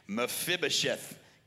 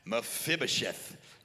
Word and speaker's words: Mephibosheth: Mephibosheth. Come on, one more time. Mephibosheth.